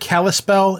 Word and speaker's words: Kalispell: Is that word Kalispell? Is that how that's pronounced? – Yep.